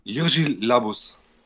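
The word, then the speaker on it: Jiří Labus: Und wie spricht sich der Schauspieler aus? Jirgi Labus. Sehr schön.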